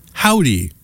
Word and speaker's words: Hovvdy: The band is spelled Hovvvd, but it's pronounced Howdy, Hovvdy.